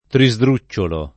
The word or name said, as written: trisdrucciolo